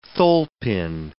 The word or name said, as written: tholepin